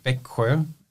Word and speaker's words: Växjö: no one can pronounce unless you're Swedish. Växjö. It's spelled V-A with two dots on top of it, X-J-O with two dots on it.